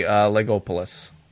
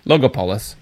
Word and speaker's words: Logopolis: And then finally, Legopolis. – Legolopolis. – Logopolis.